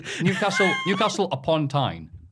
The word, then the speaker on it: Newcastle upon Tyne: By the way, Newcastle upon Tyne. – Yeah. – What do you hear? – What? – What have I just said? – You said it right that time.